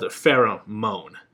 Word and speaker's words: Farrah Moan: But so for example, there is this drag queen called Farrah Moan. First name Farrah, last name Moan. If you say it right, if you say it all together, it sounds like pheromones, like women's pheromones, right?